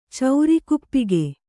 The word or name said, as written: ಚೌರಿಕುಪ್ಪಿಗೆ